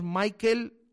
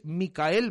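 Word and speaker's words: Mickaël: no es Michael Malsa, que es Micael Malsa.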